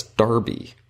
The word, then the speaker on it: Derby: For instance, the British pronounce Derby. The race for three-year-old thoroughbreds run annually at Epsom Downs, England, as Darby.